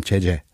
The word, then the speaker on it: 제재: the pronunciation itself is the same. Because I mean, in theory, we were supposed to pronounce them uh, like differently. – Yeah. But nowadays, everyone's pronouncing. – So the jiet is the same, jeje. – Yeah, jeje. – No different. – Mm-hmm. – All right, and one is an all e, one is an ae.